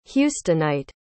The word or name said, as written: houstonite